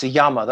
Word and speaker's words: Jammer: I mean, I was recently reading a biography of Einstein by one Max Jammer. That's spelled Jammer. It's a German word. J is pronounced as a Y.